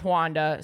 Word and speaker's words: Juana: J-U-A-N-A. – Wanda. – But pronounced Wanda.